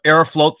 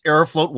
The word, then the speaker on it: Aeroflot: It Aeroflot? Whereas, I thought it's Aeroflot. – It depends on your Aeroflot pronunciation, but I think that Aeroflot sounds nicer, okay? But it is written F-L-O-T in Russian.